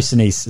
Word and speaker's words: Sinise: And I think Gary Sinise, is that how you say it? – Yeah.